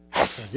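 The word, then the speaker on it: hasah (חסד): How you say it, right? Hasah.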